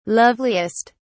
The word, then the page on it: loveliest: British English